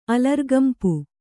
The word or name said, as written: ಅಲರ್ಗಂಪು